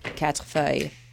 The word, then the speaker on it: quatrefeuille: You can pronounce it the French way if you would like. – Quatrefeuille. – Meaning four leaves. – But the American pronunciation works too.